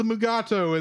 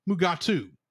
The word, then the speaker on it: Mugato: This creature was named Gumato in the script, but DeForest Kelly, who plays Dr. McCoy, could not pronounce the word Gumato, so it was changed to Mugato, which Kelly and William Shatner proceeded to pronounce Mugatu.